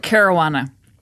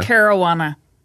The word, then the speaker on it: Carawanna: That's right, Carawanna. – Carol Carawanna. – Carawanna. – Try it again. Carol Carawanna. – That's right.